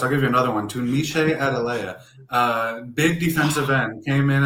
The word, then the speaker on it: Tunmise Adeleye: And then the third guy is Tumiche Adeleye, transfer from Texas A&M. Folks were really excited about in the spring. – Hold timeout. Great pronunciation, by the way.